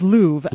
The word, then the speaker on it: Louv: I believe it's Louv, L-O-U-V.